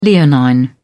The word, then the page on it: leonine: British English